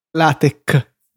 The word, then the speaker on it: LaTeX: Latex. – Latex. – Sì, si scrive latex, ma si legge latex.